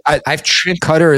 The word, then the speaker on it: Qatar: So Qatar, is that the actual? I've added Qatar is the proper pronunciation.